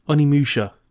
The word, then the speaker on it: Onimusha: On Minutia? – Onimusha. – Onimusha. I know I own three copies of Onimusha. You still can't pronounce the name. – For the PS2. – That's just Onimusha.